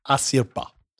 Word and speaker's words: Asirpa: Anyway, the bear then attacks Sugimoto and he is saved by an Ainu girl named Asirpa. It's a tough one to pronounce. A-S-I-R-P-A. Asirpa.